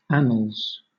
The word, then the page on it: annals: British English